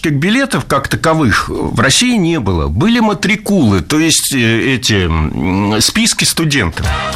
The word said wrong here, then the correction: матрикулы: Матрикулы – слово хорошее, редкое, интересное, но произнесено оно неверно. Правильно – матрикулы. Ударение ставим на второй слог.